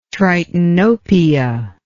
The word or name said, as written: tritanopia